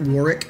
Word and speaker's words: Warwick: On LinkedIn, it's at Warwick Fairfax with the silent W in the middle, W-A-R-W-I-C-K, at Warwick Fairfax on LinkedIn.